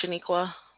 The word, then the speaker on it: Sheniqua: My name is Shaniqua. – Can you spell that, please? – S-H-E-N-I-Q-U-A.